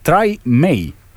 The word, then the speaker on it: Tremé: Eh, en este caso fue Emiliux eh, que nos decía: En vuestro último podcast teníais dudas de cómo se pronunciar Tremé. – en este post del New York Times dicen que se pronuncia. Espera, que ahora no vamos a reír. Algo así como Try, Try May.